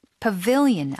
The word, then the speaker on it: pavilion: Pavilion, 아까 정자라고 하셨죠? – 그쵸? – 발음이 pavilion이고요. – Pavilion. – 예, 정자에 앉아서 and read for a while.